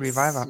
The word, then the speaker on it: Reviver: Ähm, als drittes vorab habt ihr ähm, Reviver, Reviver äh, mhm wird Reviver. Mhm. ausgesprochen äh, gemacht.